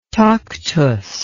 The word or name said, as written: tactus